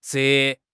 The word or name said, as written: 折